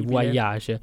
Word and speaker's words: Voyage: So la pronuncia. – Sì, il testo è in francese, quindi Voyage.